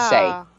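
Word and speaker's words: Lashay: is the inside of Lachey.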